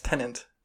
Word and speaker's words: Tenet: Yeah, it's Tenet. – There's no N in there. It's T-E-N-E-T.